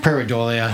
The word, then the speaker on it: pareidolia: Pareidolia? – Pareidolia.